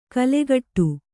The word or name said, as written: ಕಲ್ಲುಕುಟ್ಟಿ